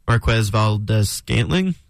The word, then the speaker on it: Marquez Valdes-Scantling: You know, the Chiefs went out and signed Marquez Valdes-Scantling, I believe is how you pronounce it. – Yes.